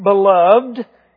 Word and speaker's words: beloved: It's really to be pronounced beloved, if you want to be, I guess, correct with enunciation.